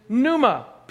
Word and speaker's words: pneuma: Well, they really are both English translations of one Greek word, and that word is pneuma, P-N-E-U-M-A. Pneuma is how it's pronounced.